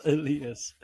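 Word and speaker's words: Ilias: Why is it spelled Elias? – It's pronounced Elias.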